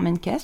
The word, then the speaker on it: Menkes: Alors juste pour l'info, Menkes, ça s'écrit comment? – M-E-N-K-E-S. – Oh, bon, comme ça se prononce, d'accord.